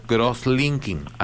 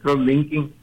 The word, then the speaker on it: cross-linking: Dice acá que usted va a explicar algo que se llama cross-linking. Así se pronuncia, ¿está bien? – Sí, fíjese sí. Que así se pronuncia, cross-linking.